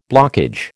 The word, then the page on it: blockage: American English